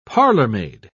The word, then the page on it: parlormaid: British English